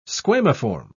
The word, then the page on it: squamiform: British English